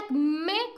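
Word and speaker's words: acmé: Pero el acme con acento en la E, con tilde en la E, quiere decir un momento culminante, ¿no?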